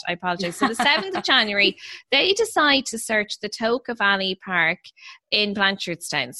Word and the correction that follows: Tolka: So Tolka, I hope I'm saying that right. – It's Tolka. – Is it Tolka? Sorry, it's the river, Tolka, isn't it?